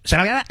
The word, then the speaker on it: Sharia: I've been mispronouncing it. Sharia law. I didn't know that's how you pronounce it.